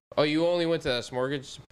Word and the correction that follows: Smorgasburg: Smor, Smor. How do you say the word? – Smorgasburg.